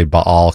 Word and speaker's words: Baal: And it's about Elijah and the prophets of Baal, or I often say Baal, because that's how you pronounce it in Hebrew.